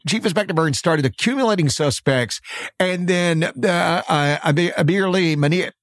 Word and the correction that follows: Amir Ben Ali: how do you say his name? I'm sorry. – Amir Ben Ali. – I'm sorry. Amir Ben Ali becomes a witness.